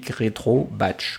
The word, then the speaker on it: RetroBatch: Ouais, Flying L Y N G M E A rétro batch comme ça se prononce.